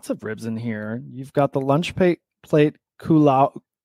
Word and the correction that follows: Kalua: Pronounce it for me. Kalua. – Kalua. – Yeah, straight up Kalua.